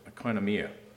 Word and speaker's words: oikonomia: The New Testament word for stewardship is, and I'm going to try to pronounce it, oikonomia.